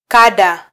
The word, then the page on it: kada: Swahili; female; Kenya